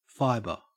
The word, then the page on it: fibre: Australian English